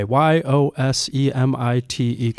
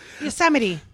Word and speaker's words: Yosemite: Could you pronounce that word for us? – Well, see? But wait, let me think. – You're doing it right now, bro. – Yosemite. – That's correct. But how did you say it when you first read it? – Yosemite.